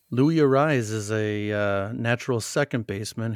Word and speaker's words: Arraez: He does the rolling of the R's with a double R. So when they announce him, they say Louis Arraez. So that's the right way to pronounce his name.